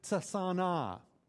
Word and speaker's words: tsana: It's the word tsana, and you pronounce both the T and the S together there.